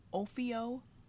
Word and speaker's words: Ophiocordyceps: There's a tropical fungus, and excuse me if I mispronounce this, but it is called Ophiocordyceps. – Close enough.